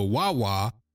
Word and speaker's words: Wawa: W-A-W-A. I guess that's how it's pronounced. Wawa.